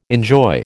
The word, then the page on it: enjoy: American English